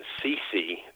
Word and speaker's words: Ceci: And of course, my work was really preceded by Dr. Stephen Ceci, that's spelled C-E-C-I, but pronounced like Whitney Houston's mother, Ceci.